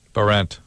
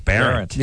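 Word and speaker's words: Behrent: Barrent. – Barrent. – No, Barrent.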